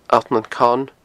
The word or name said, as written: Allt nan Con